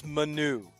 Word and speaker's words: Manu: It's Manu. The emphasis is on the U, which means the dunk, you got egg on your face from last week when you said that sounds like manure, okay?